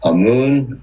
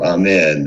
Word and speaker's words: Amun: Amun or Amen, however they wanted to pronounce it, Amun Ra was thus considered the greatest god of Egypt and who was the incarnation? – Pharaoh.